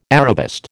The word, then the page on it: Arabist: American English